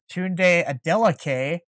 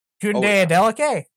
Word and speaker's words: Tunde Adeleke: Hamilton has signed Canadian defensive back Tunde Adeleke to a 2-year extension.